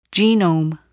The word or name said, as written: genome